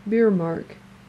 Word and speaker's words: Bjerremark: Biermark. It's spelled B-J-E-R-R-E-M-A-R-K.